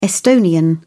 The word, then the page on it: estonian: British English